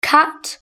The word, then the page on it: cat: British English